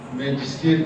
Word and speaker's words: ماجستير: فبتنطق بتعطيش الجيم، ماجستير، تعطيش لازم، لو قلت ما ينفعش اقول ماجستير، تمام؟